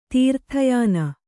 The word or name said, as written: ತೀರ್ಥಯಾನ